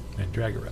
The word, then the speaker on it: Mandragora: Did I say that right? – Mm-hmm. – Good. – Mandragora. – Mandragora. – Not Mandragora.